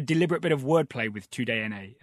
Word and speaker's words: TUDN: Yeah, TUDNA is how I think you are supposed to pronounce that.